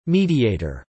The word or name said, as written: mediator